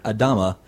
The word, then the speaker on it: Adama: Adama.